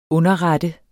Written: underret

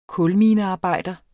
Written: kulminearbejder

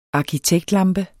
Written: arkitektlampe

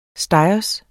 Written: stegers

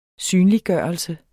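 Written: SYNLIGGØRELSE

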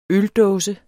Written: øldåse

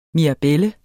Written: mirabel

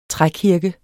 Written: trækirke

